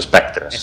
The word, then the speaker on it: Spectres: El título es en catalán. Es Spectres. Sí, sí, sí, sí. – Correcto. Es que no soy... – Pero claro, también he buscado un título que sea fácil, entendible y que se pronuncie casi casi igual que en inglés.